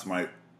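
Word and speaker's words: Smythe: So uh, it's S M Y T H E. It's pronounced smite.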